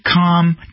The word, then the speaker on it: Khan: And London Mayor Sadiq Khan, spelled K-H-A-N, but you could spell it C-O-N, acknowledged that London streets are not safe for women and girls.